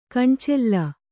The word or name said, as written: ಕಣ್ಚಲ್ಲ